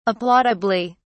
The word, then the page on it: applaudably: British English